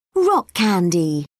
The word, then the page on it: rock candy: British English